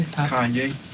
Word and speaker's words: Kanye: Kanye.